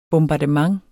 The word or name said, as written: bombardement